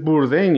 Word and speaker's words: Bourdain: Entonces lo revisé en Google para ver cómo se pronunciaba. Y efectivamente es Bourdain. Y yo, pues bueno, entonces lo estaba pronunciando perfectamente. – Porque en las noticias francesas es Bourdain. pero en ah, CNN es Bourdain.